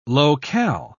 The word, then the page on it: low-cal: British English